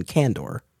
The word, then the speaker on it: Kandor: My pronunciation, my understanding, the way it's always been said has been Kandor. – Kandor, okay. Outside of the books, this is my first exposure. I don't remember this in like Superman, the animated series. So Kandor, what I thought was really helpful was it gave Superman immediate access to Kryptonian culture, immediate access.